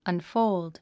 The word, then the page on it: unfold: ʌnfóuld; アンフォールド